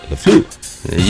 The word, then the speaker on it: LaFleur: That's how you say it. LaFleur.